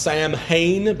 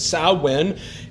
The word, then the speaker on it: Samhain: It looks like it's pronounced Samhain, but it's Samhain, and it literally means summer's end.